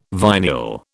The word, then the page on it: vinyl: British English